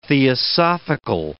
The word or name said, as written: theosophical